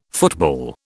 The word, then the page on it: football: British English